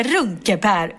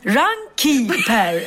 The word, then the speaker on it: Runkeeper: Hon tog fram sin mobil och visade och då insåg jag att Runkepär uttalas inte Runkepär utan Runkeeper.